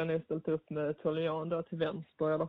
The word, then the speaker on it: Toljan: Och Jeremy, jag kanske inte uttalar rätt, Toljan från Hoffenheim. Jeremy Toljan, ja.